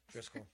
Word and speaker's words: Driskill: excuse me if I'm saying this wrong, but this one's the Haunted Driskill Hotel. – Driskill. – Thank you. – Driskill Hotel. – I've been saying Driskill all day, and I cannot say whatever it is. – Driskill.